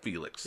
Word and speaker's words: Phoelix: Phoelix. That's how I pronounce that. Felix. – Yeah, that's right.